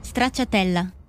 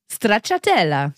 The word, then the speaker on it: Stracciatella: Stracciatella. – Habt ihr gehört? – Stracciatella.